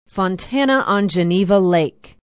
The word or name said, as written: fontana on geneva lake